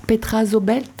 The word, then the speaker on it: Petra Zobelt: Petra Zobelt. – C'est ça, tu le prononces très très bien. – Peut-être que je prononce mieux le breton que l'anglais, finalement. Issu de ton quatrième album, Velmavin. Petra Zobelt, qui signifie « Que s'est-il passé ?» Que s'est-il passé, ouais.